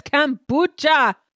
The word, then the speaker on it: kombucha: After the Zambuca? – It's not Zambuca, it's kombucha.